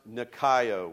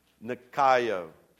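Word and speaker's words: nikaio (νικάω): It's a Greek term, and it's pronounced nikaio. Would you say that with me? Nikaio.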